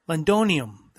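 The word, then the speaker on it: Londinium: We were talking about Londonomium. Nope, saying it wrong. Londonium. That's it. Londonium.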